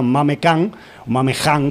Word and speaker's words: Khan: Y concretamente, pues hemos escuchado un cantante muy famoso de allá que se llama Mame Khan, si pronuncias la KH así como una J.